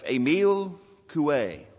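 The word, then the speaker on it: Émile Coué: While you're turning there, I want to tell you about a Frenchman by the name of Emile Coué. I looked that pronunciation up. I've been working on that French for a long time. Emile Coué.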